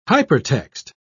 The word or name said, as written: hypertext